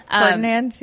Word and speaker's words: Ferdinand: So I just happened to get super lucky, and I recruited everyone but Fernandad, Ash, and then Hubert, obviously, and Didu. – Ferdinand, yeah.